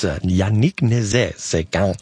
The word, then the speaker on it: Nézet-Séguin: Let's listen now to Yannick Nézet-Séguin and the Philadelphia Orchestra. I always say it wrong. I'll probably say it wrong in the future, but it's Yannick Nézet-Séguin and the Philadelphia Orchestra here with Florence Price's Symphony No.